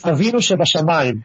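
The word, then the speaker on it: Avinu Shebashamayim: Avinu Sebastia Magim. Is that correct? The pronunciation? How's my pronunciation there? Did I get that? – Not exactly. – Okay. – It's Avinu Sebashamayim.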